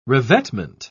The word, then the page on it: revetment: British English